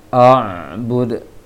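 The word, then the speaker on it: أعبد: Usahakan dilebarkan bibirnya untuk pengucapan ain sukun, karena tidak ada penyebab ain tersebut untuk ditebalkan. Oleh karena itu bacanya jangan dibulatkan bibirnya ketika baca ain. Wala la a'bud, tapi biarkan dalam seperti apa baca la, lebarnya bibir ketika baca la, maka seperti itu juga membaca ain, posisi bibirnya.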